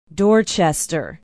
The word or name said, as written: dorchester